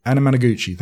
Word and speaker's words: Anamanaguchi: Anamanaguchi, thank you.